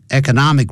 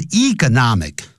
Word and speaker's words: economic: Economic recession 也可以念成 economic 也可以念成 economic 两个念法都可以